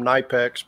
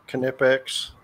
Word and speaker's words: Knipex: I call them Knipex, but Knipex, as you pronounced it.